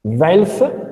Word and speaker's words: Welf: Velf e vai Blingen. Va bene? – Velf e vai Blingen. Sono due castelli che fanno capo a due famiglie aristocratiche. Una di Svevia e l'altra di Baviera. Velf, Baviera, Svevia, Weiblingen.